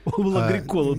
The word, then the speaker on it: Агрикола: Из Санкт-Петербурга Эйла написала, вы знаете, в финском языке ударение всегда на первый слог. Михаил Агрикола тоже на первый слог. Извините, говорит, слух режет.